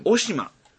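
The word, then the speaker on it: 渡島: あしからず、はい、渡る島と書いて、うん、お島。と読みますと。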